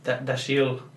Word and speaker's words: Dashiell: No. But it looked more like how it's actually pronounced. – Yeah. So Dashiell. Mm-hmm. That's my best Scottish accent off the top.